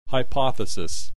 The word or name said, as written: hypothesis